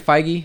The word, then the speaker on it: Feige: But it also doesn't even make sense because Kevin Feige, how do you say his last name? – Yeah, you got it right.